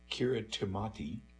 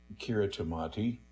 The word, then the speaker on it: Kiritimati: Teitgen's time on Kiritimati, and it says here, in parentheses, pronounced like Christmas, okay? It's spelled K-I-R-I-T-I-M-A-T-I, pronounced like Christmas.